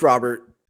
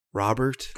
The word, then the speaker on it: Robert: It's Robert. I've heard it a bunch of different ways. His uncle has told me that it is pronounced Robert.